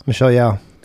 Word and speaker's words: Michelle Yeoh: Michelle Yeoh.